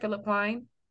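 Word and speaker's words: Philipp Plein: Am I saying it right? Philip Klein? – Yeah.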